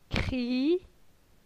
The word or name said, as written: Cri